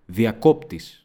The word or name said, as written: διακόπτης